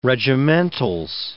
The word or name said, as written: regimentals